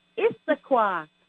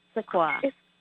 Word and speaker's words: Issaquah: It's pronounced Issaquah. not Issaquah. It's Issaquah.